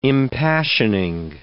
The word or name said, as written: impassioning